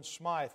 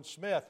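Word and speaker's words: Smyth: Some pronounce him as Jonathan Smythe, but Jonathan Smith, who was the one who founded the Baptist Church.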